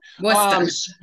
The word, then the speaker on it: Worcester: Worcester. – Okay. – Worcester.